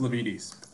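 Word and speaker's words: Lavietes: But I do like Harvard to cover the 3 points at, was it Lavetti's? Is that what it's called? – I believe it's pronounced Lavides Pavilion.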